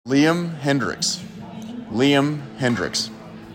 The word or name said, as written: Liam Hendriks